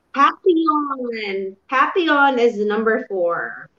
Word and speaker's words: papillon: Yung spelling niya kasi is papillon. Pero ang pronunciation niya, it's really quite different. It's the papillon.